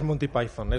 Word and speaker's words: Monty Python: Es Monty Python, es Monty Python y mucha gente los llama los Python nada más, de Pythons.